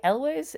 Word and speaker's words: Elwes: And Carrie, I'm going to say Elwes, is that how we pronounce this man's name? – I'll agree, yeah.